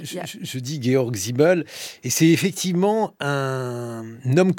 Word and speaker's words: Georg Simmel: » Oui, Georg Simmel quelqu'un... – Ah, déjà, mettons, voilà, moi je dis Georges Simmel, et je savais que j'allais être retoquée immédiatement Moi je vais le prononcer en français, parce que vraiment...